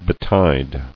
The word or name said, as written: Betide